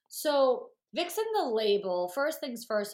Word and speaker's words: Vicsen: Is Vixen the label? – Vixen. Victoria, and like Vixen being sexy, like okay. V-I-X-E-N. So this is Vic, V-I-C-S-E-N. Vixen.